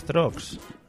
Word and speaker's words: Torx: T-H-R-O-X. – Ah, es que es Torx. Madre mía. Es que, claro, con esa pronunciación...